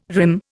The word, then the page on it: rim: Indian